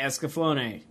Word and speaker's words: Escaflowne: Ducknuts wants to know, what's the correct way to say Escaflowne? Well, I just said it. – You said it, yeah.